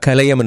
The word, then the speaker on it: கலையம்: அது சரியான ஒரே உச்சாடல், அதாவது உச்சரிப்பு என்பது கலையம் என்றே வரும்.